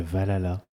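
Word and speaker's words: VA-11 Hall-A: Moi, je dirais Valhalla. Alors, qui s'écrit VA-11 All-A.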